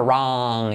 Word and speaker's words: wrong: Letra clave R, la W como si estuviera, la O como una, wrong. Y la G última que no se dice, wrong, that's wrong.